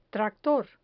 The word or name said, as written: tractor